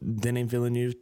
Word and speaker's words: Denis Villeneuve: Yeah, hey, just so you know and our listeners, I listened to a director's cut with Denis Villeneuve today, and we've pronounced it right since episode two.